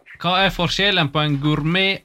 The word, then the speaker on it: gourmet: Gourmet, eller jag vet inte hur det uttalas det här. – Gourmet. – Lite som fransé, ja.